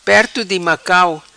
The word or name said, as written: Perto Di Macau